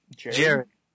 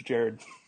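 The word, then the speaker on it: Jarrod: Gerard Saltalamacchia. Is that how you say his name? – Jared.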